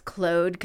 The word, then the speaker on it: Claude: Claude comes from the Latin. Okay, it means lame, but I love the sound of it. Especially the French pronunciation, Claude.